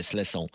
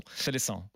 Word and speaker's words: Seleção: c'est d'ailleurs pour la prononciation, c'est comme ça. Tu dis juste pas le e, le premier, et tu dis se laissant. Se laissant.